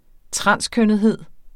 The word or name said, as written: transkønnethed